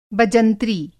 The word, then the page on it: बजंत्री: Hindi